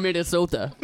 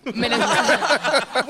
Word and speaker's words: Minnesota: I'm from Minnesota. – Minnesota. Which is not even how they say it. It's Minnesota.